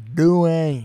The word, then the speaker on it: Duane: I've never seen Dwayne spelled like that before. – It's so weird, right? – D-U-A-N-E. – And E, Dwayne. – I thought it was Duane. – Nope, it's Dwayne.